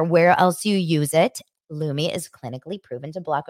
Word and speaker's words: Lume: Lumi, it's spelled L-U-M-E.